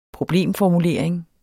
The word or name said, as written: problemformulering